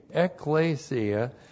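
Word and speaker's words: Ecclesia (ἐκκλησία): And then we have the object of that preposition is ekklesia. E-K-K-L, long E-S-I-A, Ecclesia, and this is the church, and here refers specifically to the local church.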